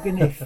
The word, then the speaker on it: Gniff: now, is it Gniff or Gniff? – Gniff. – It's a Swiss origin, which is probably why. It's spelled G-N-I-F-F.